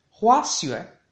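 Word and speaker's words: Hua Xue (滑雪): Esquí en chino se dice Hua Xue, Hua Xue, Hua Xue, con un segundo tono en la A de Hua y un cuarto tono en la A de Xue.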